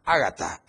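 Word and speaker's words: Agatha: Agata, Agata es, no es Agata, es Agata, así como se pronuncia, Agata, de categoría Dos, impactará las costas de Oaxaca y, en las próximas 12 horas.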